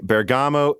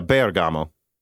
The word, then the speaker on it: Bergamo: QH Freddy writes him, again, since you brought it up in Loose Ends, let me just say Bergamo is pronounced with emphasis on the E. Yeah, Bergamo.